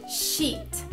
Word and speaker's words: sheet: Então, a pronúncia, mais uma vez, é sheet. Sheet. Aqui você tem que tomar cuidado com a pronúncia, porque esses dois es, ele dá uma alongada na, no som, então fica sheet.